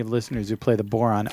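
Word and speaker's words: bodhrán: Yes, I was just want to point out that Ken Larson's signature on his email says, Boron, it rhymes with moron.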